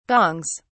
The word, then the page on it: gongs: British English